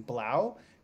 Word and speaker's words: 3LAU: So his DJ name is it's spelt 3LAU, but it's pronounced, if I'm not mistaken, Blau. Because his full name is Justin Blau.